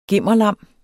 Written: gimmerlam

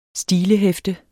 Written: stilehæfte

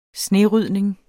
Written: snerydning

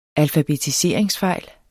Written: alfabetiseringsfejl

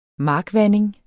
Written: markvanding

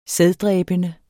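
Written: sæddræbende

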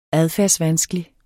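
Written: adfærdsvanskelig

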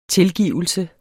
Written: tilgivelse

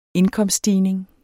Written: indkomststigning

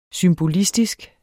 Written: symbolistisk

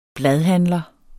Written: BLADHANDLER

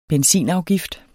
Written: benzinafgift

